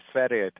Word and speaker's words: Sverige: Yeah, thanks a lot, and a very, very good pronunciation of Sverige to start with.